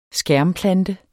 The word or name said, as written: skærmplante